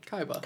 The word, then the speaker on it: Kaiba: No, that was me attempting to figure out how to pronounce. K-A-I-B-A. – Kaiba.